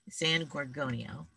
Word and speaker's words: San Gorgonio: So we have two poems today from Marco Capifari, and we're going to start with San Gorgino, Gorgonio. Just looked up how to say it. San Gorgonio.